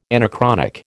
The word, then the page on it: anachronic: American English